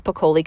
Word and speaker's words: Piccoli: Again, if you enjoyed this episode, please screenshot it and tag me on your Instagram story, Julia Piccoli Coaching. My last name is spelled P-I-C-C-O-L-I.